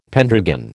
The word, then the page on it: pendragon: American English